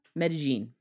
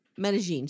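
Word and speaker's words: Medellín: How do you say that? – Medellin. – Medellin. Sorry.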